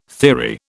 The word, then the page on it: theory: British English